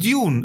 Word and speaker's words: Dune: Nein, es heißt Dune. Geh auf Google und lass es aussprechen. Die sagt Dune.